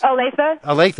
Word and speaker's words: Olathe: Calling from Olathe. Am I pronouncing that right, in Kansas? – Olathe, yeah.